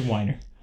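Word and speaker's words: Weiner: Say his name. Matthew Wiener. it's a whiner.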